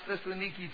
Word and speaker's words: Thessaloniki: People in Thessalonica or Thessaloniki, it's pronounced Thessaloniki today.